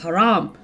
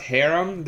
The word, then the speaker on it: haram: They've been saying it very bogan. – What are some of the pronunciations you get? – We got harem. That was the funniest one. – Like a harem, a multi-wived. – Yeah. Changes the whole meaning of the term.